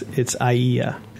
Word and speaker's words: Aiea: it's Aiea, and you pronounce each of the vowels. It's A-I-E-A.